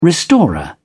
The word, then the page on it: restorer: British English